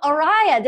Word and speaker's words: Arriah: Please welcome Araya. Did I say that right? – Yes, you did say it right.